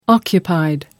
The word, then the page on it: occupied: American English